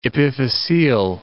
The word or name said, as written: epiphyseal